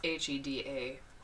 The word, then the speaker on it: Hedaya: I'm sorry, I don't know how to pronounce his last name. It's Hedaya.